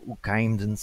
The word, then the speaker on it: Oukaïmdoun: M-O-S-S. Only you will be able to pronounce this correctly as we speak, but it's the Moroccan Oukaïmdoun Sky Survey, and I'm sure I did not pronounce that correctly.